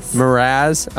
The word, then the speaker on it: Mraz: Jason Mraz. Is that how you say his name? – Mraz. – I mean, it feels like there should be some more vowels in there.